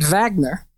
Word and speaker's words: Wagner: And he called it the Wagner Group after the name of a composer by the last name Wagner, but we would pronounce it Wagner because the man is a German composer.